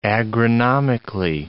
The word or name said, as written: agronomically